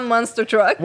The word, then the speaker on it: Monster Truck: Le Monster Struck. Le Monster Struck au Stade Olympique. Il y a plein d'hétéros. – Où ça? – Ça, je l'ai pas réussi à le prononcer. – L'événement Monster Truck.